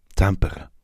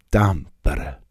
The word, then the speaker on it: Tampere: Tampere. In Finnland betont man meist die erste Silbe eines Wortes und das R wird gerollt. Tampere.